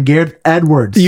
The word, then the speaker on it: Gareth Edwards: Gareth Edwards.